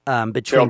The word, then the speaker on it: Terrell: Did I say that right, Terrell? – Terrell, Texas.